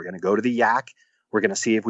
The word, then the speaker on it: Yaak: But there is one spot in northwestern Montana called the Yak, and it's spelled Y-A-A-K, and it's in the Yak River Valley.